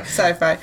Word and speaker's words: Sci-Fi: Sci-Fi or sci S- I'm pretty sure it's pronounced Sci-Fi. Sci-Fi.